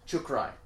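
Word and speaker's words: Chukhrai: whereas this Grigori Krychak, super hard to pronounce. Yeah. Chukrai. Grigori Chukrai is kind of considered, like, the young pup.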